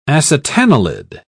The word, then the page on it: acetanilid: British English